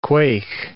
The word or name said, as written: quaigh